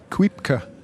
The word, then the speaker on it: Kuipke: We are outside the Kuipke track. – Can you spell that for me, please? – It's Q-U-I-P-K-E. It's a tricky word for English speakers to get their tongues around, really, because it's very Flemish.